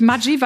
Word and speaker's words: Maggi: Das heißt wirklich Maggi, weil ich meine, das kommt aus der Schweiz und es wird wirklich so ausgesprochen.